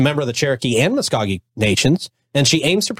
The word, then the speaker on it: Muscogee: Did I say that right? – Muscogee? Yes, you did.